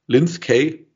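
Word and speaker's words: Lynskey: von der Melanie Lisinski, Linsky, wie wird das ausgesprochen? Linz Kay, ja.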